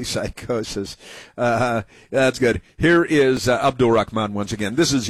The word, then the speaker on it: Abdul: We gotta say it like that, man. It's creepy. – Abdul. – That's how it's pronounced. – Holds on to the L a little too long.